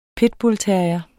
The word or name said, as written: pitbullterrier